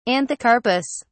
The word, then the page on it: anthocarpous: British English